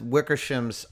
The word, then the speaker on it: Wickersham: How much of Seth Wickersham's article, I believe that's how you say his name, Wickersham, how much of Seth WikiWiki's article do you believe is actually true? – I'd say at least 85% of it.